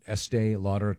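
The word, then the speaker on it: Estée Lauder: Is it pronounced Estee Lauder? Estee Lauder, yes.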